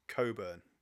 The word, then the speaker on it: Cockburn: If anyone who doesn't live in Perth, they wouldn't know anything about this, but there's a place called Cockburn, and it is the only known instance in the English language where a CK at the end of a word is pronounced as ugh, and it becomes Coburn.